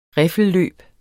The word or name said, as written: riffelløb